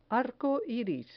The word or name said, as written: arco iris